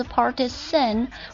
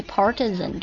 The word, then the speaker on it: partisan: 好，我们看最后一个，最后一个的话应该很多同学见过啊，应该是见过的 partisan，它可以读作 partisan 或者 partisan 都可以的，形容词表示盲目拥护的，偏袒的，就是有点偏见的这种感觉， partisan， P-A-R-T-I-S-A-N。